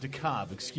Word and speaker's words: DeKalb: So Cedric Alexander is from DeKalb, Georgia, or DeKalb is pronounced DeKalb.